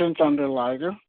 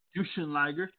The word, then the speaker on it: Jushin Liger: Now, for last year's Hall of Famers, we got JBL, we got Justin Liger, I believe. That's how you pronounce it. – Jushin Thunder Liger. – Jushin Liger.